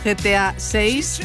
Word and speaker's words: GTA 6: Y hoy tenemos una cita para los amantes de los videojuegos, GTA 6.